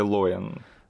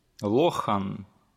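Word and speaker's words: Lohan: А, давай сразу тогда договоримся, Лохан или Лоин? – Подожди, я слышал, как э, англоговорящие чуваки произносят ее имя, и я там отчетливо услышал букву Х. Лохан. Да, она не такая ярко выраженная, как русская, но все-таки это не, не майяха.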